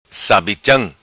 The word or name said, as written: Sabicham